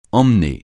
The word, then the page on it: emmener: francais